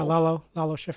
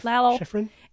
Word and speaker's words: Lalo Schifrin: I don't know how you say his name. That's how I say it. Lalo. – Yeah, Lalo Schifrin. Lalo Schifrin.